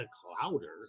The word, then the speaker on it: clowder: And it's spelled like C-L-O-W-D-E-R. So it's not like cloud, like you figure they're fluffy, right? No, it's like chowder with the clout in front of it.